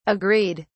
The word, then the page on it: agreed!: British English